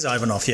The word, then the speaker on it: Ivanov: It is Ivanov, yes.